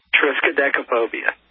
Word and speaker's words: Triskaidekaphobia: Triskaidekaphobia.